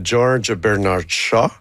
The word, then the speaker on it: George Bernard Shaw: Ben, l'inspiration vient de la pièce de George Bernard Shaw, que j'ai prononcé comme à merveille.